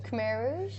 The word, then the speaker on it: Khmer Rouge: Khmer Rouge.